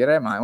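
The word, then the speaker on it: Re:Mind: Kingdom Hearts 3 come si pronuncia? Re Mind? – Quell'R sì. come, è così, Re Mind.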